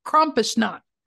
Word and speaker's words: Krampusnacht: Yes, let's pronounce it correctly and then dive right in. Krampusnacht. – Am I saying it wrong? – No, we're saying it two different ways. – You're saying it so correctly. I cannot be more sure.